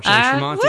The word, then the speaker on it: Jay Tramonte: You are, Jay Tramonte.